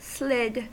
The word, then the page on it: slid: American English